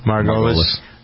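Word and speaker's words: Margulies: Chris Noth, Juliana Margulis, is that how you pronounce her name? – Margulis.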